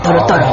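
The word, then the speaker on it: Totoro: Ah, Toro Toro.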